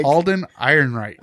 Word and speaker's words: Alden Ehrenreich: I just never say his name right. Alan Ehrenreich? – Alden Ehrenreich.